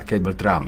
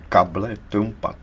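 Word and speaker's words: cable trampa: To se vyslovuje možná cable Trump. – Ne, to se vyslovuje kable Trumpa. To je španělský termín značící nástražný drát k mně. – Aha. – Čili past, nástražnou past, kable Trumpa ve španělštině.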